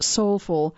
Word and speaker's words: SOLFL: The new endeavor is called SOLFL, pronounced Soulful, an acronym that means Student of Life for Life.